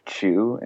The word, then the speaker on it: Chueh: Okay, well, my name, as you know, is Luke Chueh, and that's spelled C-H-U-E-H, and I am a Los Angeles-based painter, an artist, and I'm also a nerd.